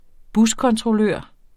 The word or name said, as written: buskontrollør